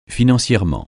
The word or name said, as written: financièrement